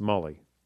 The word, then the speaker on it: Mali: Yes, Mali.